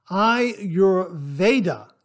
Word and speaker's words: Ayurveda: As one who has had to relearn the pronunciation of turmeric and not turmeric, I was taught the pronunciation of Ayurveda is Ayurveda. Now, Ayurveda is spelled A-Y-U-R-V-E-D-A.